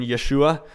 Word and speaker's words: Yeshua: Jesus, or the Hebrew pronunciation Yeshua, means the Lord saves.